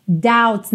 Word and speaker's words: doubts: Doubts, não se pronuncia a letra B, então você não fala doubt, e sim doubts.